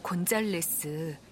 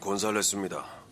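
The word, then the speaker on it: González: 소피아 곤잘레스. 옆에 적힌 이 숫자는 전화번호인 것 같은데 제가 스페인어는 전혀 못해서 스페인어 발음으로는 곤살레스입니다.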